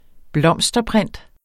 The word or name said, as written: blomsterprint